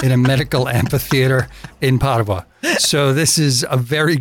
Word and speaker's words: Padova: Padova, as it's pronounced in Italian, is yet another jewel that should be on your list.